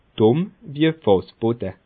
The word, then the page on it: Dùmm wie e Fàssbode: Bas Rhin; Schiltigheim